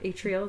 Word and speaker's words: atrial: Is that atrial? Is that how you say it? – Yep.